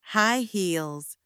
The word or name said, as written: high heels